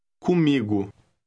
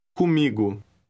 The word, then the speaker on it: comigo: Look at word 3, paying attention to the nasal OM combination. – Comigo. – Did you get the stress on that word? – Comigo.